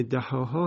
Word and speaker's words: دحاها: اینجا وقتی ما تلفظ میکنیم کلمه دحاها هم استثناء هست از امالهی که بیان کردیم.